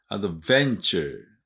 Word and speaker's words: adventure: adventure, ven のところにアクセントあります。